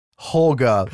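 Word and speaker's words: Hårga: It almost sounds like Holga the way it's pronounced sometimes in the movie. But I'm just going to say Harga because I'm an American.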